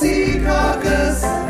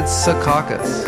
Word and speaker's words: Secaucus: Yes, and it's actually pronounced Secaucus. – Oh, it is? I've always said Secaucus. – Yeah, a lot of people do.